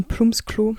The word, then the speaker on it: Plumpsklo: Es gibt ein Bumsklo in der Nähe vom Haus. Ein Plumpsklo, genau so spricht man das aus.